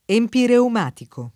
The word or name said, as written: empireumatico